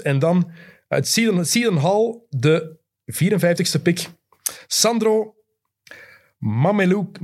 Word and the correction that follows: Mamukelashvili: Mame, ik kan het zelfs niet uitspreken. Mamukelashvili.